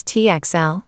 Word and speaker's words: Tuchel: Thomas Tuchel, German pronunciation, Thomas Tuchel,